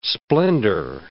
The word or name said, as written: splendour